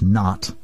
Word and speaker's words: Nath: perhaps more correctly pronounced Nath tradition, although it's spelled N-A-T-H.